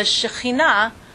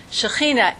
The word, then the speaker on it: Shechinah: Shekhinah, S-H-E-C-H-I-N-A-H, Shekhinah. – Shekhinah? – That would be, if one didn't know how to say the ch, then they would pronounce it like that, but it's a Shekhinah.